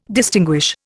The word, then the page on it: distinguish: Indian